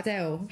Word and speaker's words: Adele: He came and asked me how I say my name, and I was like, Adele.